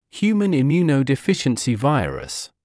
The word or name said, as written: human immunodeficiency virus